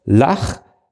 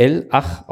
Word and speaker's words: L-Ach: Lach würde das ja eh, ich würde das ja eher L-Ach aussprechen. – L-Ach? Okay.